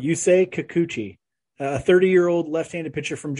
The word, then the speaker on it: Kikuchi: I'm going Yusei Kikuchi. – You're close. It's Kikuchi.